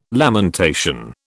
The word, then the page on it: lamentation: British English